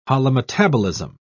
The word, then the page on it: holometabolism: British English